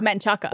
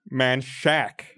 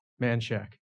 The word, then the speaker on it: Manchaca: Manshack. – Manshack, yeah. – Manshack. – Oh, see, they went with the SH. – Interesting. That's interesting. I would have gone with the CH, but yeah.